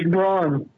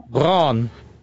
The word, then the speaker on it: Braun: Well, isn't the proper German pronunciation Brown? – It's Braun. – Is it? – Yeah. I thought slightly longer. Yeah. – Braun.